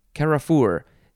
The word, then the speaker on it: carrefour: Next we have, I think it's pronounced Carrefour. Carrefour. C-A-R-R-E-F-O-U-R, noun from the 15th century.